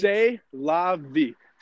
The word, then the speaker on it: C'est la vie: C'est la vie. C-E-S-T-L-A-V-I-E. It's a French word.